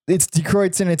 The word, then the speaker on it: Die Kreuzen: It's spelled Die Kreuzen. it's Die Kreuzen. It means the cross or to intersect in German or something.